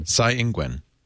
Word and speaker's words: Tsai Ing-wen: Say it again. – Tsai Ing-wen. – Tsai Ing-wen. – Ing-wen. – Ing-wen. – There you go. That's better.